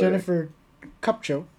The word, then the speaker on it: Jennifer Kupcho: They Jennifer don't even let play Jennifer Kupcho.